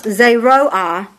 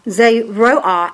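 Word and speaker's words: zeroa: And it's the word, it's spelled Z-E-R-O-A, Z-E-R-O-A, that's the Hebrew word for vegetable or pulse, okay? And it's pronounced zeroah, zeroah, and it simply means something that is sown.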